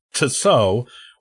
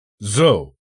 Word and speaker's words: Zuo: We've all probably stumbled on how to properly pronounce his name, which is often either pronounced phonetically as Tso, or more like So, without the T sound. The general's real surname was actually Zhou, which is pronounced Z-U-O, rather than the So that we call him.